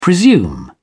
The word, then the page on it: presume: British English